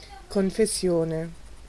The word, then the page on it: confession: [kɔ̃.fe.sjɔ̃]; France